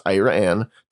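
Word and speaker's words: Iraan: It's spelled almost like the country Iran. It's I-R-A-A-N, but it's pronounced Iran, Texas.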